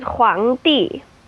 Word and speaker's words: Huangdi: How would you say that? – That is Huangdi.